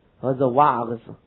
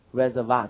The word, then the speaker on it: reservoir: 他说，canals and reservoirs have been built。那么你们读成什么？reservoir 对不对？那个是英语，reservoir 这是法语。